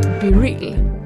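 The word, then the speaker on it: BeReal: Det här är det sjuka, att i App Store, då står det så här, det uttalas Be Real.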